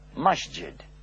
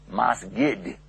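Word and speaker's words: masjid (مسجد): Now, some people call it mosque because years ago, when the Europeans heard the word, they heard it from the Egyptians who pronounced it masgid. And so they thought they were saying mosque. And that's where the word mosque comes from.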